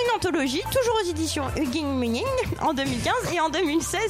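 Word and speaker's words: Huginn & Muninn: Vous avez aussi écrit plusieurs livres comme Super-héros, deux points, une histoire française aux éditions Eugene Munin, je ne sais pas comment on prononce. – Eugene Munin, d'accord.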